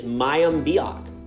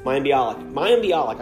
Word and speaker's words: Mayim Bialik: And another addition to the guest host list is Mayim Bialik. Did I say her name right? – Yes. – Mayim Bialik.